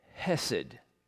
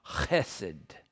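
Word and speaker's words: chesed (חֶסֶד): In Hebrew, the steadfastness of God's loving care is called hesed, or I should get the pronunciation right, for in Hebrew, it begins with this guttural sound, chesed.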